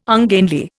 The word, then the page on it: ungainly: Indian